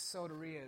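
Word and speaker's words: soteria (σωτηρία): That idea of saved in the Greek, it's soteria is how you say that.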